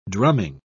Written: drumming

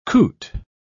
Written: coot

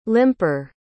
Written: limper

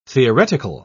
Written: theoretical

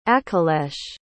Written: akhilesh

